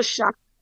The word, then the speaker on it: shuck: No, it's called a shuck. Shuck. – Spell that. – S-H-U-C-K.